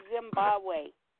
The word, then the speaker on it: Zimbabwe: I pronounced it wrong. I said Zimbabwe. And Nick was like, you mean Zimbabwe.